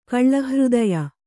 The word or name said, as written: ಕಳ್ಳಹೃದಯ